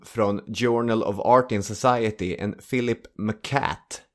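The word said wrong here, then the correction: McCouat: Eller McCuett kanske ska uttalas.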